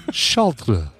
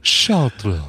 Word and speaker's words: Chartres: And the name of this French city is pronounced Chartres. Chartres.